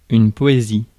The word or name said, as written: poetry